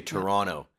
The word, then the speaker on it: Toronto: so we don't pronounce the last T. We say Toronto and Ottawa.